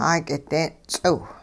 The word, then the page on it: 'aak'ide'n ts'iw: Hupa